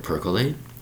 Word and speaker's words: percolate: Three syllables for the word percolate, pronounced with percolate, with three syllables, spelled P-E-R-C-O-L-A-T-E.